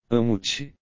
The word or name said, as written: amo-te